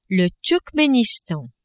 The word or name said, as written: Turkmenistan